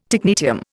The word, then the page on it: technetium: Indian